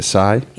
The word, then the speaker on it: Tsai: Is it Tsai? – Yeah.